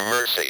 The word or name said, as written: mercy